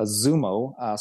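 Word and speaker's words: XUMO: It's spelled X-U-M-O, a Zumo, so you pronounce it as if it's a Z.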